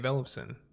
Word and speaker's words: Ellefson: by Dave Mustaine and Dave Ellefson.